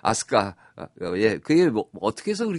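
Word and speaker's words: 아스카 (飛鳥): 그 거기도 아스카라고 그랬는데 여기는 날빛자에다가 세조자를 써서 그 비조라고 쓰고 그 아스카라고 발음을 해요.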